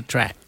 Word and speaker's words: tract: it sounds the same, but it's not spelled with a K. It's spelled with a T, T-R-A-C-T.